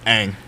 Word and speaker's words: Ng: I pronounce it correct? – Anna Ng. Yeah. Yep. Ang.